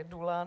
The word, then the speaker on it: dula: že ho budeme psát tak, jak se vyslovuje, čili dula, ne doula, jak vlastně to i někdy úplně v počátcích, kdy se tady to slovo objevilo, někdy psalo.